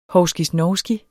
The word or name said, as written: hovskisnovski